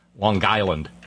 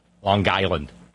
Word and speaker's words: Long Island: As I was listening to the program, all of a sudden the brilliant Todd from South Carolina mentioned Suffolk County, Long Island, or as it's pronounced thereabouts, Long Island. You got to pronounce the G, Long Island.